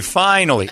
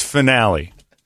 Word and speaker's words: finale: Do you think at the end of something I go finale or do I say finally? It's finale.